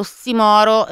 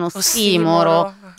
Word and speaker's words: ossimoro: Tra l'altro devo dire che mi ha sgridato Roby perché ho detto ossimoro e non ossimoro. E in realtà stavo dicendo ossimoro, poi comunque ossimoro non è sbagliato, è più corretta la pronuncia sdrucciola, hai ragione Roby, però ossimoro si può dire, volevo solo dire quello.